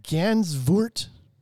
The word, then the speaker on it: Gansevoort: Uh, Gansevoort, New York.